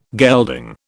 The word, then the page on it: gelding: British English